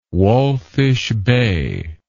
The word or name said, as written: walfish bay